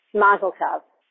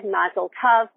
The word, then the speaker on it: mazel tov: mazel tov, mazel tov. Okay, that's the ah sound for the O, which rhymes with love.